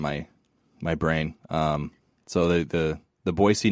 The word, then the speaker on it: Boise: It's a soft S. it's important to get it right. – Do they get sensitive about it? Boise? They want Boise? – I don't know that they do, but someone who's from Idaho told me a long time ago that it was kind of an annoyance. Like, it's not Boise with a Z, that it's Boise. So it's an S. You pronounce the S like an S.